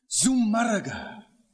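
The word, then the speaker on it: Zumárraga: Bishop Zumarraga, Z-U-M-A-R-R-A-G-A with the accent on the U.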